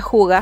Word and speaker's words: hygge: Otra cosa de, las que, de la que les estuve hablando el año pasado fue sobre Hygge, que es este concepto danés que se escribe H-Y-G-G-E, Mucha gente lo pronuncia juga, está súper bien, denle nomás, cada uno lo pronuncia eh, como le parece mejor.